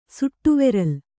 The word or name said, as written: ಸುಟ್ಟುವೆರಲ್